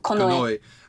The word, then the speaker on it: Konoe: Konoe.